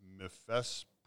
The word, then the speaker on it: mifepristone: Jewish federations are deeply troubled by the federal judge ruling on Mifepristone. I can't pronounce that word. – I think that you did a good job.